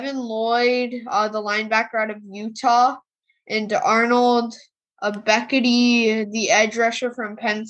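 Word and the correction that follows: Ebiketie: I think it's pronounced Ebeketti.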